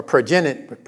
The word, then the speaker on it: progenitor: Or progenitor, that's how you say it.